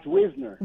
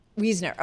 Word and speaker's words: Weisner: It's Weisner. – Weisner. Forgive me. My bad. Chuck Weisner. – Like W-I-Z. Weisner. Yeah. – Weisner.